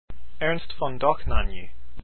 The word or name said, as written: dohnanyi